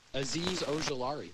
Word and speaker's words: Azeez Ojulari: Azeez Ojulari.